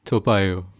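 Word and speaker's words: Toa Payoh: Did I pronounce that right? Toapayo? – Toapayo. – Toapayo. – Yeah.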